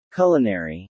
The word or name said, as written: culinary